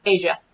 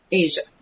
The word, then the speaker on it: Aja: Oh, and Aja is apparently pronounced Asia. – Asia. – Oh. Well, that's a bummer. Because I pronounced it like the Steely Dan album.